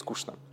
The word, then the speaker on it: скучно: А, поэтому я говорю скучно, и я всех всегда поправляю. Я просто слышу, что и в песнях поется скучно, там и Земфира так пела, и а, группа Буйрак, например. А, правильно говорить скучно и петь в том числе в песнях.